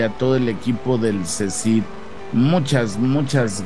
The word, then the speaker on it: CSIDH: Cecid se escribe C-S-I-D-H. Aquí lo estás viendo abajito de mí.